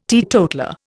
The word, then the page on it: teetotaller: Indian